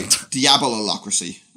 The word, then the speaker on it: diabolocracy: Diablocracy. Di- sorry, diabolocracy. Uh, that's a, how do you pronounce diabolocracy.